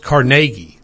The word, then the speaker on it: Carnegie: but technically the name is pronounced Carnegie. Did you know that? Yeah, that's 100% true. You can fact check me on that. But it's Carnegie is the way it's originally supposed to be pronounced. Somehow, some way, over the centuries, it's gotten to be called Carnegie, but no.